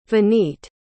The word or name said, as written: vineet